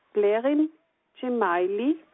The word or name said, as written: Blerim Dzemaili